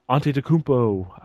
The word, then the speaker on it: Antetokounmpo: I'd love to see Antetokounmpo. I keep saying it correctly, and I'm so proud of myself.